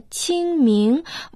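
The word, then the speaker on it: Циньмин (清明): Сезон Циньмин можно дословно перевести как «сезон чистоты и ясности». Иероглиф «цин» произносится в первом тоне. В переводе на русский язык это «чистый, чистота». А иероглиф «мин» произносится во втором тоне, значит «ясности, ясны».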